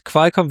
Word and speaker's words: Qualcomm: Und Qualcomm wird mit KW ausgesprochen anscheinend. – Qualcomm, ja. – Also so eher wie doch, Qual, da, Qual.